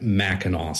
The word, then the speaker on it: Mackinac: But generally, it's always kind of tied back to that Mackinac sound.